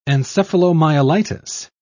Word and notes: encephalomyelitis: British English

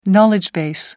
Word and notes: knowledge base: American English

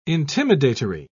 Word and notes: intimidatory: British English

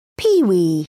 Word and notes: peewee: British English

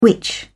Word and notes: which: British English

